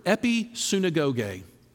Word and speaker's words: episunagoge: Turns out that's actually only one Greek word, and it's the Greek word episunagoge. Now, the second part of that word ought to sound a little bit familiar because it sounds like the word synagogue.